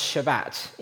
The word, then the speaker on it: Shabbat: So the Sabbath in its original language is pronounced Shabbat in Hebrew, and it's translated as to stop.